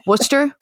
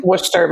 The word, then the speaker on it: Worcester: Like Worcester, Mass. – For some reason? – For some reason. – It's like, yeah. Okay. Well, that's where it is. – It's like Worcester sauce.